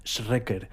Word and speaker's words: Schreker: Se escribe Schreker. Schreker.